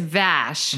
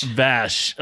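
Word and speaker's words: vache: In French, Vash, I hope I'm saying that right. – It's Vash. – Vash. Okay. Mm-hmm. So so if anyone wants to know how it's spelled, it's V-A-C-H-E. And he has in parentheses pronounced pretty much the same way as her name.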